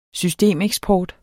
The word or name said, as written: systemeksport